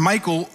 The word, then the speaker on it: Michal: And Michal, the daughter of Saul, and I don't know how else to pronounce it than Michal, and I even looked it up on Google to make sure, you know, that that's how it's pronounced.